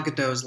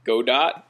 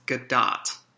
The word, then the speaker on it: Gadot: How do you pronounce Gal Gadot's last name? – Godot? – Prince. – Because I've been hearing lately that it's Gadot and not Godot, because she's Israeli.